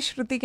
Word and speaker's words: Shruti: Well, अगर आप भी इस लिस्ट में कोई मूवी ऐड करना चाहो, then feel free to share that with me, मिर्ची श्रुति के नाम से मैं फेसबुक, ट्विटर और इंस्टाग्राम पे मिलूंगी।